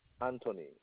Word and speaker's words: Anthony: Anthony. – Tony, can you spell it for me? – Anthony. A-N-T-H-O-N-Y. Anthony. – And can you spell your last name for me? – Gitarre. G-I-T-A-R.